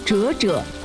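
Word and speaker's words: 啫啫: 满哥告诉味道调查员，滋滋的粤语发音为折折，人们便将其命名为折折包。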